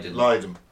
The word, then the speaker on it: Lydon: Former Sex Pistols vocalist John, how is it pronounced? It's Lydon.